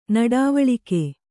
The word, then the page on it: ನುಡಿವಳಿಕೆ: nuḍivaḷike